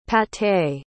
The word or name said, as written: pâté